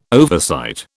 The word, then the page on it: oversight: British English